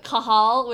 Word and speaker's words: Cathal: Yeah, there's a D-G-H-E. – That's pronounced good. – D-G-D-H-G. – It's pronounced good. – And then there's Kahal, which I think is Hachal. Kahal. It's C-A-T-H-A-L. But it's not pronounced like Cathal.